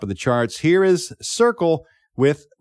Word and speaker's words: Cyrkle: but instead he gave it to The Circle. By the way, their name is spelled, if you're looking to look it up, C-Y-R-K-L-E.